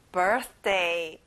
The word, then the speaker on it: birthday: 생일, birthday. 가운데 TH 발음까지 정확하게 해보세요.